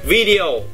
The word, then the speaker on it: video: Nhấn đầu tiên video chứ không phải là video cũng sai luôn đấy.